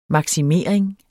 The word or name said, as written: maksimering